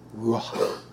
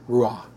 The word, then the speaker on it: ruach: Ruach. can't pronounce the H too much, but ruah.